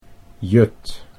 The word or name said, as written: Jut